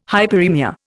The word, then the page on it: hyperaemia: Indian